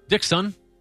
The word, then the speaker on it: Dikson: D-I-K-S-O-N. – Dixon. – Okay. I was thinking more Dixon. – All right. – Yeah. – Dixon.